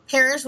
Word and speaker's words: Parish: I say parish with one R, like the church, not the death.